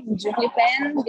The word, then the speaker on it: Giuvlipen: Because during the pandemic, we couldn't perform anymore in our theater company, Giovli Pen. This is how it's pronounced, but it's fine, it's okay.